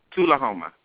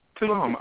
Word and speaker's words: Tullahoma: Tullahoma. – Tullahoma.